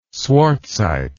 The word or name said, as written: swartzite